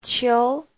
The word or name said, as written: qiu2